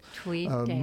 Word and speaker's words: Tweeddale: Tweeddale. Most people, there are two D's. – Oh, yes. Oh, yeah, because it's a compound word. It's both words. I pronounce both D's.